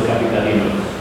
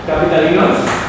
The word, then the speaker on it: capitalinos: Primero, la epidemia de influenza humana afectó la salud emocional de los capitalinos. ¿Qué palabras tienen esas dos? ¿Capitalinos? Capitalinos. Termina en S, entonces el acento natural está en la penúltima sílaba.